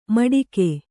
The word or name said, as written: ಮಟಿಕೆ